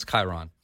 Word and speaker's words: Chiron: It's not Citron. It's Chiron. – Chiron? – It's the Bugatti Chiron.